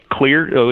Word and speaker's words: Xlear: Well, they can learn more at our webpage, Clear, which is spelled X-L-E-A-R.